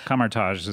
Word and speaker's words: Kamar-Taj: I just said Kamar-Taj, but I don't know if that's correct. – Kamar-Taj. – Yeah, Kamar-Taj is how they pronounce it, yeah.